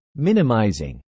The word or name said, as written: minimizing